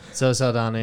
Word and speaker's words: Zoe Saldana: Zoe Saldana, yeah.